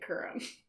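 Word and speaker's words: Kurim: I'm talking about the Corum case. Are you familiar with this case? – How do you spell that? – K-U-R-I-M. I'm pronouncing it Corum. – No. Curum? Curum? – I'm pronouncing it Curum.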